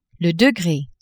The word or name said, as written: degre